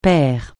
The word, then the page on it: père: francais